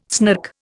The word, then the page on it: snark: Indian